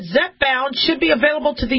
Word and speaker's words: Zepbound: Zepbound, or Zepbound, but I think it's pronounced Zepbound, is a new weight loss drug from drug maker Eli Lilly.